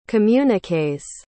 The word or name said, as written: communiques